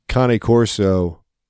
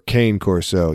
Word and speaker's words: Cane Corso: Which is funny because his breed, which is Cane Corso or Cane Corso, which is how it gets pronounced over here, literally just means dog running, like a dog that runs, which the vaguest name and also not particularly accurate.